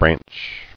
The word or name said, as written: Branchy